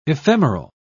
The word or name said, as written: ephemeral